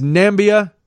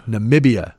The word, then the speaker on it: Namibia: And the president mispronounced this country as Nambia. It is M-A-N-A-M-I-B-I-A. – Namibia? – Namibia. Correct. Namibia.